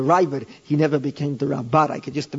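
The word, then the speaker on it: Raavad: the Rabbad, you know who I mean. The proper pronunciation, as they taught me in Israel, is the Rabbad, but it's very, very difficult for me to leave the Ravid if whenever the Rav would,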